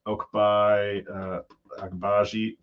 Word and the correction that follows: Ochai Agbaji: Sorry, I definitely butchered that name. – But Ochai, yeah. – Surprised. Yeah, Ochai Agbaji.